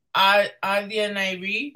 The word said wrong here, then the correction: Asia Nairi: Asia Nairi, that's my bestie.